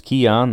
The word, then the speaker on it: Keion: 285 pounds out of Gardner, North Carolina, 24.2 years old, a little bit older, pronounced Keon, that's pretty straightforward.